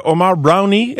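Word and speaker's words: Omar Browne: Omar Brownie, c'est comme ça qu'il faut le prononcer, semble-t-il.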